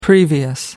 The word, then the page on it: previous: American English